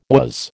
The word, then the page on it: was: American English